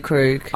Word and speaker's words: Krug: Is it not pronounced Krug? Is it Krug? – I think I'd say Krug.